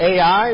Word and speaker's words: Ai: And Joshua 7 tells us what happens in the next chapter. When they're going to the next battle that they were going to face, the city of Ai, or we might pronounce that I, as they go in and they go into battle, all of a sudden they're defeated.